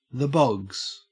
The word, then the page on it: bogs: Australian English